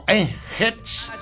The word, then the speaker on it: Hatch: Dice que para él es lo mismo Hatch, no México, ¿verdad? Pero tengo entendido que la H y la A se pronuncian como Hatch.